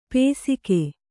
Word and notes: ಪೇಸಿಕೆ: pēsike

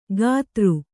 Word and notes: ಗಾತೃ: Kannada

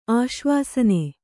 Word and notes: ಆಶ್ವಾಸನ: Kannada

